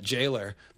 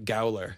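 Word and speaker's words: gaol: So the antiquated way of spelling that of jailer is G-A-O-L-E-R. And you actually still say that, jailer. But for the longest time, I thought it was like a different version of the word, and you say it gowler. So when I did the last podcast, I pronounced it gowler and then figured out that I was wrong and actually ran back in and edited it before I put it out.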